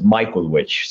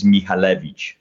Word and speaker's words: Michalewicz: It depends. I left Poland when I was six, and I ended up westernizing my last name, and I actually pronounce it Michalewicz.